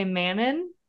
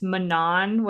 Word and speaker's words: Manon: So yeah, so depending on how you want to pronounce it, if you read the book without knowing the pronunciation, you would say Manon. But if you learned afterwards, it's Manon, which is like the, the grossest sounding name I think I could ever hear.